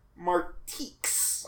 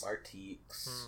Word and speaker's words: Marteex: But this next brand, like, I had looked at it several times, and I think it's spelled M-A-R-T-E-E-X. Martiques. – Martiques. – It's on a couple things. – Martiques. – I thought it might be like Martinez, but no, that's clearly an X.